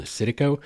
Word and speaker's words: Citico: And it had multiple spellings and multiple pronunciations throughout history and by different people, from Setico to Citico to Satapo. So just work with me. Citico Creek is how it's spelled. That's how I'm pronouncing it tonight.